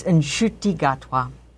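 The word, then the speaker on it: Ncuti Gatwa: We want a correction on the pronunciation of the gay star of Doctor Who. – Oh, well, my understanding was it's Nshuti Gatwa. – Yes, that is his name.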